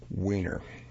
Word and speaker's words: Weiner: Last week I mispronounced the gentleman's name, Weiner, and unfortunately he actually pronounces it Weiner.